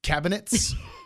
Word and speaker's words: cabinets: Cabinets? – It's three syllables. Cabin. Cabinets, like a picnic basket.